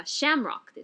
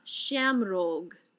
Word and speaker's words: shamrock: で、どういうことを、まあ、セントパトリックスデーにしたり、まあ、どういうことが、まあ、シンボルというか、まあ、セントパトリックスデーといえば、まあ、この一番最初に思い浮かぶのがシャムロックですね。アイルランド語の発音ではなんかシャムローグ